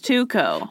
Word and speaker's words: Tycho: But he's Danish, and it's a haunted language. Don't at me. It's Tycho.